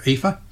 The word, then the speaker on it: Aoife: My granddaughter is called Aoife, mm-hmm. A-O-I-F-E, yes. which Australians struggle with. Because they want to pronounce it phonetically and they don't know how.